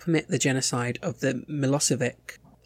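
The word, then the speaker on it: Milošević: I think it's Milosevic, but you were pretty much spot on.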